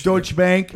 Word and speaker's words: Deutsche Bank: And then it came out that the Deutsche Bank, is that how you pronounce it? – Deutsche Bank fucking gave him a loan that he should have gotten.